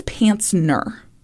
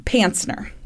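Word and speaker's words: Pancner: One of the psychiatrists who examined him was Ronald Pantzer, which I really enjoy that last name. – Mm-hmm. – Actually, I think it's Pantzner, but it's spelled with a C, P-A-N-C-N-E-R, Pantzner.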